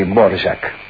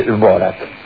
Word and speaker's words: Dvořák: Dvořák se escribe Dvorak, se pronuncia así, Dvořák. Si usted quiere lo dice así, si no, le dice Dvorak. Y está bien igual.